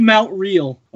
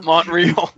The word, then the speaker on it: Montreal: But I'm going to learn how to say Montreal. Not Montreal. Well, I know it's Montreal. I know that. – You're still overpronouncing the T.